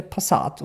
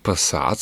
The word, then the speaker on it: Passat: Und jetzt stell dir mal vor, der wird Passat so aussprechen, wie die meisten Leute in Deutschland glauben, dass Wiener Passat aussprechen. Also Passat. Wenn die jetzt die Vokale noch einmal langziehen, jetzt hast du Doppel A.